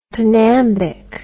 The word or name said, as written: pnambic